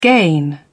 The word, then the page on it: gain: British English